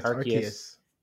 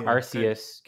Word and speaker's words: Arceus: I looked it up, how to pronounce it. It's 100% Arceus. It's not Arceus because I knew I was going to like think of that the first time I said it on the podcast.